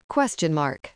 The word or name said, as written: question mark